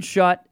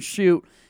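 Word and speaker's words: Schutt: Jaden Shoot, I was saying that wrong. Not Jaden Shut. It looks like it might be pronounced Shut, but it's Jaden Shoot, and he can shoot the basketball, so that makes sense.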